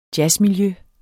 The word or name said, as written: jazzmiljø